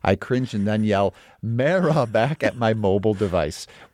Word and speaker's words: Mara: The Mara family has always pronounced their surname as Mara, not Mara.